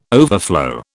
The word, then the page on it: overflow: British English